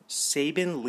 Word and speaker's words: Saben: Now, let us do the random player, Saben Lee of the Phoenix Suns, kind of like Nick Saban, but spelled with an E.